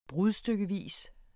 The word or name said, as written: brudstykkevis